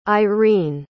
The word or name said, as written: eirene